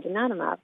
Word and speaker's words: donanemab: And donanemab, the new drug, yes, you're pronouncing it correctly, works with the body's immune system to target amyloid and to try and break it down.